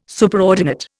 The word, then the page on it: Superordinate: Indian